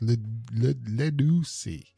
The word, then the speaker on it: Ledoussi: Ledoussi. That's how it's supposed to be pronounced. Ledoussi, which is from the Yoruba, meaning to bring forth.